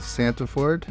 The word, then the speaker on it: Santeford: We have my friend, Amanda Santeford.